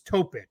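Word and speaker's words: Topić: Isaiah Collier, it's Topic. So you say it kind of like Jokic. – Okay. – I know it's spelled Topic, but it's one of those accents on the C.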